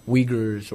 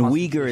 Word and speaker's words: Uyghur: And Uyghur is spelled U-I-G-H-U-R. I want to be clear. U-I-G-H-U-R. It's pronounced Uyghur. Well, at least we pronounce it Uyghur. How do your people pronounce it? – Uyghur. – Wow. Say it again. – Uyghur. – That's tough. – Yes. – That's tough. So we're going to stick with Uyghur, U-I-G-H-U-R.